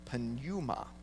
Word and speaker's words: pneuma: Because that word spirit is pneuma, which is the same root word where we get the word pneumonia. It is the pronunciation in English is P-N, you pronounce the P, but P-N-E-U-M-A.